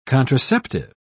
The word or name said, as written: contraceptive